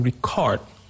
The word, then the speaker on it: record: Example, we have the word to record. When I say to record, it is a verb. So the stress is on the second syllable, to record.